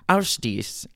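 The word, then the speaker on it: Ásdís: Okay, I was pretty sure I said that wrong, and so I Googled the, like, pronunciation guide. Let's try that again, shall we? Have a listen. It almost sounds like she's saying, how's this? Ausdies.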